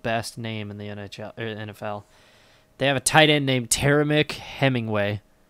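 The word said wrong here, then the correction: Temarrick: Or, sorry, Temerick Hemingway. Said his name wrong.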